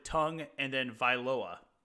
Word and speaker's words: Tagovailoa: And he explicitly says, you say the word tongue and then Viloa.